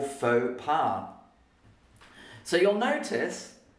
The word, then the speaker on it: faux pas: Faux pas, We don't say the X and we don't say the S because it's a French word. Faux pas.